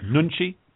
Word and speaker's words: nunchi: Is that pronounced correct? Nunchi? Nunchi? – Yeah. You must be Korean, because your pronunciation is spot on.